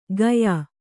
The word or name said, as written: ಗಯಾ